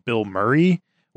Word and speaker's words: Bilmuri: Then the headliner was Bill Murray, which is spelled The band. – The band Bill Murray. Yeah. It's spelled BILMURI, which, Brian, it sounds like you know the old singer of Attack.